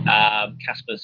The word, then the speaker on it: Caspar: So the first thing is just stick my name into Google, Casper Craven. There's there's only one of me, Casper, C-A-S-P-A-R.